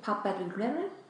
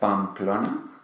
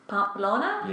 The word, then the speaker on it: Pamplona: How do you pronounce it? – Pampelune? – Yeah, that's what I said. – Yeah. How do you say it? – Pamplona. – Pamplona? – Yeah, that's it.